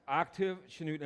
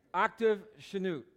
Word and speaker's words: Octave Chanute: But Octave Chanute. And I checked the pronunciation 4 times, and I checked it with my wife, Sue, and she's always right. So she's not here. I get to say that.